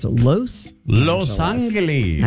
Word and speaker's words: Los Angeles: Jamie Lee pronounces the loss like it rhymes with gross. So I guess she says Los Angeles.